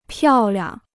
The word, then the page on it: 漂亮: piào liang